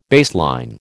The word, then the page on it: baseline: American English